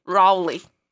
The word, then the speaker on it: Raleigh: What's the proper pronunciation? – Raleigh.